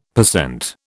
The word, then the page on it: per cent: British English